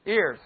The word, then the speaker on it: heir: How do you say that word, H-E-I-R? Ear. See, it sounds like hair.